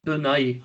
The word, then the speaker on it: Bill Nighy: Äh, Bill Nighy? – Bill Nighy, ja.